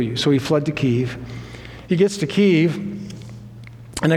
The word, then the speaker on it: Kyiv: By the way, it's Kyiv or Kiev. Either way is okay. Kyiv is the Ukrainian pronunciation. Kiev is Russian pronunciation. Either works.